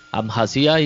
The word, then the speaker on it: Abjasia: Abjasia y Osetia del Sur lo, pronunciaste perfecto.